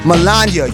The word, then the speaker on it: Melania: Melina Trump. Melania. You say her name right, she got a friend named Anya.